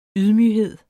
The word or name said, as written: YDMYGHEDEN